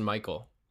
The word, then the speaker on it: Mikhail: You played Mikhail. – Yeah, it's spelled funny, but it's actually just Michael.